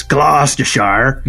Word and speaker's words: Gloucestershire: So the area where I live is called Gloucestershire, which if you ever see the word written down, it does not sound like it's spelled... – Gloucestershire! – Well, it is kind of written Gloucestershire. And most people really struggle with it, but it's just Gloucestershire.